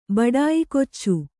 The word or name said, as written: ಬಡಾಯಿಕೊಚ್ಚು